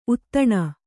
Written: ಉದಾನ

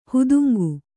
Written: ಹುದುಂಗು

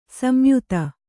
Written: ಸಂಯುತ

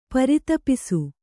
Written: ಪ್ರತಾಪಸ

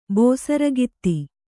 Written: ಬಸಿರ್ಕುತ್ತ

